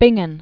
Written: Bingen

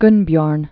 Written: Mount Gunnbjorn

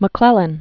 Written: McClellan

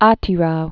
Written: Atirau

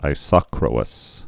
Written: isochroous

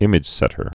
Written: imagesetters

